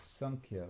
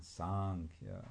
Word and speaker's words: Sankhya: And in the purport, Prabhupada speaks of two different things, Sankhya with a short A and Sankhya with a long A.